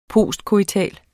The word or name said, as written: postcoital